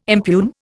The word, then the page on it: impugn: Indian